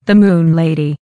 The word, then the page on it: the moon lady: American English